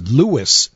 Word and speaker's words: Lewes: And then the town just north of it is spelled L-E-W-E-S, Lewis, Delaware.